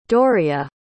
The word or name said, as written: Doria